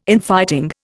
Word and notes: Infighting: Indian

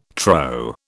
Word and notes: trow: British English

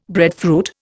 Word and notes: breadfruit: Indian

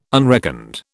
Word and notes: unreckoned: British English

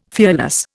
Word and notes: fearless: Indian

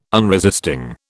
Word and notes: unresisting: British English

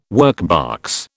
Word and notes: workbox: British English

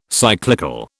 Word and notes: cyclical: British English

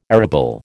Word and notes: arable: American English

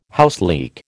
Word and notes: houseleek: American English

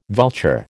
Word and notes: vulture: American English

